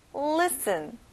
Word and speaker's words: listen: 듣다, 귀 기울이다, listen. 여기도 역시 가운데 T 발음은 안 나네요. listen.